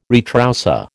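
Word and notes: retroussé: American English

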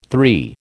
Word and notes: three: American English

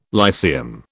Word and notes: Lyceum: British English

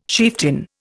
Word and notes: chieftain: Indian

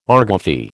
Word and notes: argufy: American English

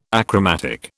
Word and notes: achromatic: British English

